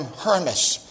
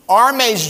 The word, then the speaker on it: Hermes: Some people call him Hermes. You pronounce it Arme. If you're familiar with high fashion accessories, one of the best is a very expensive French extra known as Arme.